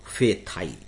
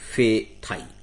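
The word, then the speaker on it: Phethai: इस तूफान का नाम है फेथाई, जिसके इंग्लिश में स्पेलिंग होगी PP for Patna, H for Haryana, E for England, फे THAI फेथाई।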